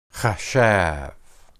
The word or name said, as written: hashab